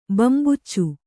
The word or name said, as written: ಬಂಬೂಚು